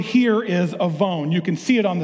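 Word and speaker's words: avon (עָוֹן): It's spelled with a W. You pronounce that W like a V. I couldn't write A-V-O-N because I didn't want a line of pink cars out here next week saying that I'm calling their company sin. If you don't get that, ask your parents. It's pronounced avon.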